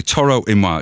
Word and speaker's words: Toro y Moi: Toro y Moi, it's spelled T-O-R-O-Y, just the letter Y, M-O-I. As in moi.